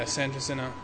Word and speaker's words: San Jacinto: How is that pronounced? Am I getting that right? – Yeah, San Jacinto.